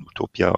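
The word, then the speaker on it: Huttopia: schreibt mhm. sich HU, Utopia, spricht man Utopia aus.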